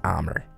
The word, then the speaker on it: Amir: I asked him, like, A-M-I-R? Yeah, he said. So his name is Amir, right? Yeah, Rob said, but he pronounces it Amr.